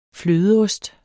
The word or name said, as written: flødeost